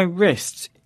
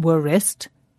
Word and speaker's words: wrist: We don't say wrist. We say wrist. Wrist. And some other words that start with a silent W are, Rob? – Right. That's W-R-I-T-E. Like to write a letter. And wrong. W-R-O-N-G. Meaning not correct. In fact, there's a rule here, which is we don't usually pronounce the W in words that begin W-R.